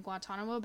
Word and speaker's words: Guantanamo: He was assigned to an 18-month tour in Guantanamo Bay.